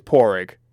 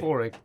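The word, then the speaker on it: Pádraic: I think it's Porrig. It's not spelt that way. – Yeah. – But I think it's pronounced that way. – Porrig, yeah. Yes.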